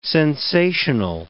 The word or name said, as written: sensational